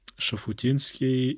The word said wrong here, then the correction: Шуфутинский: А, Шуфутинский, да, по-моему, кстати, правильно говорить.